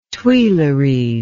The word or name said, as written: Tuileries